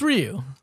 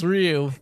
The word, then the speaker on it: Ryu: So what is the proper pronunciation? – It's Ryu. – Okay, so it's not Ong, it's Aang. – It's Ryu.